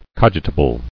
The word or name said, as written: Cogitable